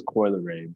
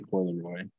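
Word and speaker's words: Coi Leray: Sure, I'm pretty sure it's Coy Leray, but you say Coy Leroy.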